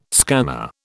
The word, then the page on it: scanner: British English